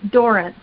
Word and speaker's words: Dorrance: And yes, and we say Durant. – Okay. – That's how we pronounce it.